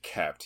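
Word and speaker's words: Koepp: And it has David Koep, I don't know how you pronounce this. – It's David Koep.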